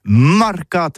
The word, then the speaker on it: marcats: Eh bien, disons à tous, mais le mercat de l'Uni ou le mercat des rebelles, mais en gascogne toulousano, c'est prononcé sur les marcats, avec un A.